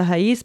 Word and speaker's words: raiz: Raiz tem sua sílaba tônica no I, mas não tem nenhum acento, porque o Z puxa a sílaba tônica. Porém, a forma plural, raízes, tem um acento, porque o Z não é mais a última letra da palavra.